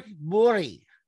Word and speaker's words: Borg: But apparently it's the G at the end of the Borg is pronounced as a Y. So it's more Bori.